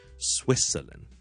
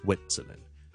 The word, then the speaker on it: Switzerland: 嗱，瑞士呢个名咧，听住 SWITZERLAND。我是读呢 Switzerland, 不是 Switzerland, 大家听着 Swit, 有个 T 音利尖顶住上面门后面那些少少肉的个位 Swit T 音，但我不用 T 出来的 Swit 跟住 Switzerland 就不要读成呢 Switzerland, 不是 Switzerland, 是 Switzerland, 大家练多次慢慢来的 Swit Switzerland.